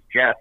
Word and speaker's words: Geoff: Well, thing is, like, when you're, like, doing everyday life, like, for me, like, I'm G-E-O-F-F. Like, you say your name is Jeff, and you don't, like, there's certain situations where you need them to put it the right way.